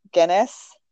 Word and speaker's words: Guinness: For St. Patrick's Day, the beer company, and I'm probably going to butcher this, is it Guinness? – Okay, yes.